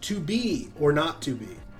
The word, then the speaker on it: 2BR02B: But it is pronounced 2B or not 2B. 2B or R, 2BR. Not zero, not to be or not to be, in reference to the famous line from William Shakespeare's Hamlet.